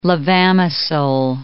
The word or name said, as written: levamisole